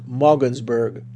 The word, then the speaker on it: Maugensburg: That city in Maryland is spelled MAUGENSBURG. I believe it's pronounced Mogensburg. And finally, 1670 CJEU, Gatineau, Quebec.